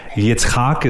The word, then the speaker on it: Yitzchak: Yitzchak is maybe a more correct way of pronouncing it in Hebrew.